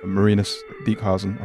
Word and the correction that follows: Marinus Dijkhuizen: Again, I think I'm saying his name incorrectly, but I'll go with it. – Marinus Dijkhuizen. – There you go.